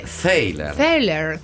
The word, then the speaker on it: Thaler: C'è stato un grande dibattito sulla pronuncia di questo cognome, ma l'Accademia di Svezia dice Thaler, quindi Thaler.